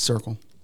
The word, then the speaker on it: Circool: It is Circle. – C-I-R-C-O-O-L.